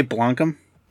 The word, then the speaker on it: Blomkamp: I say Blomkamp.